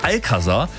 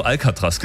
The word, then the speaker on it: Alcazar: Legendärer 2000er Disco-Klassiker von, ich muss es richtig aussprechen, die Band heißt Alcazar. Mhm. Ich habe schon so oft im Radio Alcatraz gesagt, diese Gefängnisinsel.